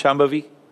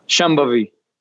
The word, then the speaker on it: Shambhavi: Can you pronounce Shambhavi? – Shambhavi. – Yeah, that's great.